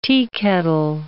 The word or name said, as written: teakettle